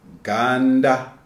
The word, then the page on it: Ganda: Shona